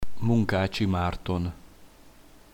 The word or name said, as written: munkacsi marton